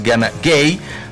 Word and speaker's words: Gana Gueye: Lamine Camara, porque también tiene su Lamine allá en el Senegal, está en la media cancha junto con Gana Gueye, quiero decir, la pronunciación es Gana Gueye.